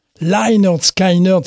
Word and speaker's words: Lynyrd Skynyrd: Si vous aimez Lynyrd Skynyrd, Lynyrd Skynyrd, c'est comme ça qu'il faut le prononcer, je fais toujours l'erreur.